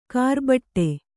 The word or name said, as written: ಕರ್ಬೆಟ್ಟು